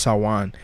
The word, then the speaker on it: Samhain: These celebrations focus on the ancient feast of Samhain, pronounced Samhain, and sometimes spelled Samhain, from which many of today's Halloween traditions originate.